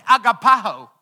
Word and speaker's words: agapao: Say agapao. If you want to say it legit, you got to say it like that.